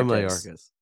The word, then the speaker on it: Mayorkas: Alejandro Mayorkas.